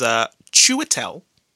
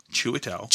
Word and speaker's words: Chiwetel: So, the actual correct pronunciation of this man's name, this Hollywood actor's name, is Chiwetel. – Chiwetel.